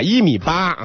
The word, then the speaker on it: 一米八: 一米八啊，发音发清楚，最后一个不是轻声，一米八、嗯、啊。